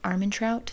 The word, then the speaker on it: Armentrout: Armentrout, is that how you say it? – Yeah.